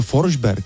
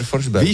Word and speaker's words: Forsberg: A počkej, Forsberg, teď je to Forsberg. – No to je pravda. – Počkej, Forsberg. – Peter Forsberg. – Víš, jakože prostě je Forsberg, ne?